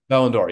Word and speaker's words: Ballon d'Or: Oh, Ballon d'Or? – Ballon d'Or, yeah, Ballon d'Or.